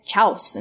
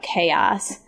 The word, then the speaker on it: chaos: I'm always reminded of, in my class, we had once, this word, and every kid kept on calling it chouse, and there was a lot of chouse happening in this text. And I wasn't sure what they were talking about, and eventually it dawned on me that they were talking about chaos. It's just spelled kind of funny.